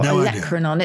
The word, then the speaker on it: olecranon: The first of my three is olecranon. Do you know what this is? Your olecranon.